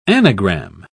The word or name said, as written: anagram